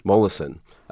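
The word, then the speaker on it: Molaison: Mollison.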